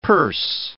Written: per se

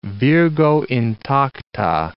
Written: virgo intacta

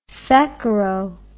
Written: saccharo

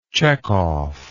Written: Chekovian

